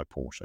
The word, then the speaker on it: Porsche: Yes, designed by Porsche.